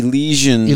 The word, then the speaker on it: Elysian: The Elysian.